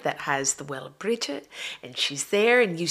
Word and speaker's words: Brigid: So, you know, if you're talking about more of like an Irish pronunciation, it's Brigid.